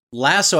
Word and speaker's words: Lasso: I always pronounced it Lazo because Lasso, I always felt like, had two S's. – Right, which is correct. – So I've always pronounced it Lazo just out of default. But I mean, I don't mind pronouncing it Lasso if that's what everyone else wants to go with.